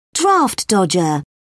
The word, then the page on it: draft dodger: British English